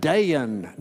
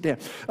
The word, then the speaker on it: Dan: You know, I found out those Mississippi girls were real sweet, but they couldn't say my name with just one syllable. Dayan. No, it's not Dayan. It's just Dayan.